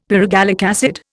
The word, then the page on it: pyrogallic acid: Indian